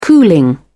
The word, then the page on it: cooling: British English